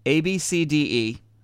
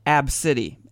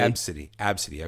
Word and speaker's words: Abcde: Her name is ABCDE. That's her first name. – Right. – ABCDE Redford is her name. – It's pronounced ABCD. – Ab-city, actually. – Ab-city. Okay, I thought it was ABCD.